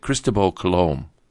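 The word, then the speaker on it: Cristóbal Colón: Okay, I'm pronouncing it the way you're supposed to say it, Cristobal Colon.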